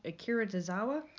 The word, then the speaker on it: Akira Tozawa: I'm going to say it wrong. Akira Tozawa. – You nailed it.